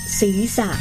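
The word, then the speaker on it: ศีรษะ: เขียนศิระสะอ่านว่าศีสะ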